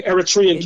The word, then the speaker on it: Eritrean: at the Eritrean. Did I say that right? – Right, the Eritrean Community Center.